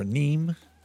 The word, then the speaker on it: Anim: But the real quick tour is we have a couple, Kenneth, Anim, or Neem, depends on how you pronounce it. Seth and I have been talking about this. I've been going back and forth on it. It's spelled A-N-I-M.